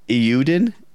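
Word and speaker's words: Eiyuden: Like we said earlier, the Iuden, that's how you say it.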